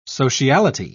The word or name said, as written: sociality